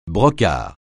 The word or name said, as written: brocart